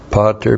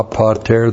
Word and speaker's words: pater: Definite article ha, pater, P-A-T, long E-R, ha pater, the Father.